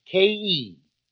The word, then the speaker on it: Khei: It is spelled K-H-E-I, but pronounced like K-E.